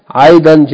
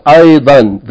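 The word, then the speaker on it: aydan (أيضا): Now the word aydan, which means beautiful, which means also, it is pronounced aydan. The tanween is pronounced. Unless if you use it at the end of a sentence, then the rules of tanween, yani the alif means that we're not going to pronounce the tanween. We're going to pronounce it as a long vowel. Ayda. That's only if you stop on it or it's at the end of the sentence.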